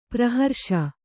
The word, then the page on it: ಪ್ರಹರ್ಷ: praharṣa